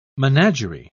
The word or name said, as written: menagerie